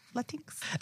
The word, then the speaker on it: Latinx: Which reminds me kind of like Tinkerbell, but that's fine. – Yeah. Yeah. – No, that's how I'll remember to pronounce it that way. With a little like Tinkerbell. Latinx.